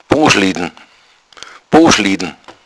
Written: boschliddn

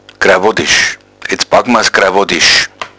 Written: grawoddisch

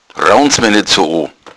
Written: raunzen

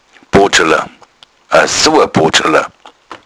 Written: bodschala

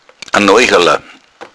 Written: noichala

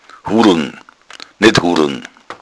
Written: hudeln

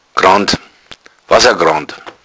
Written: wassergrand

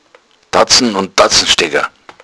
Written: tatzen

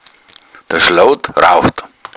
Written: schloud

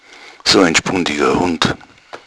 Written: spundig

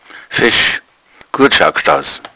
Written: fesch

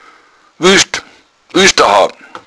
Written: wuescht

